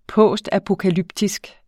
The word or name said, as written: postapokalyptisk